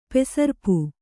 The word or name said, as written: ಪೆಸರ್ಪು